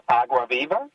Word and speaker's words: Agua Viva: The pit is called Agua Viva, and I think you pronounced it quite nicely.